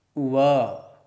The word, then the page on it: v: sanskrit